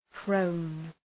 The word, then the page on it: prone: prəʋn